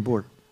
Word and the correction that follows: Bure: Candice Cameron Bure. – Bure Nisam znao da se izgovara bure, bure.